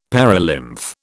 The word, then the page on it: perilymph: British English